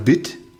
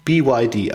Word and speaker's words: BYD: Sagt man dann BIT oder BÜT? – Nee, BYD. – BYD, also was sind das für ein beknackter...